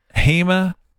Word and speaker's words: Hemochromatosis: I've got a list, but what is Hemochromatosis. – And you pronounced it perfectly.